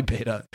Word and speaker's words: Beta: You got to pronounce that right. Yeah. The word's definitely beta. Come on. Beta male. – Beta Ray Bill. – Beta fish. – Well, in Canada, it's beta.